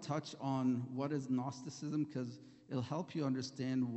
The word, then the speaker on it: Gnosticism: And then there was also some believers in, it's called Gnosticism, the G is silent.